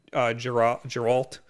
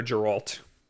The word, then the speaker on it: Geralt: It's not Witcher 3 Geralt. It's a much younger Geralt. I'm definitely saying that wrong. – Yeah. – Geralt.